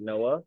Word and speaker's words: Noa: And then I went back and listened and she was like, it's spelled like N-O-A. And I was like, oh my gosh, like that's what you pronounce it, Noah.